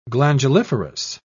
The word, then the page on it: glanduliferous: British English